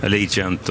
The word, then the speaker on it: Allegiant: Allegiant och Idaudi Tiaro. – Allegiant, att, var bra uttal.